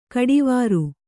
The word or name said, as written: ಘಟ್ಟವರೆ